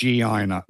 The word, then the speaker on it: Guyana: I would say it's Guiana. Guyana has got a U in front of it, so it's a hard G. Otherwise, it's a soft G.